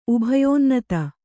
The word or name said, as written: ಉಭಯೋನ್ನತ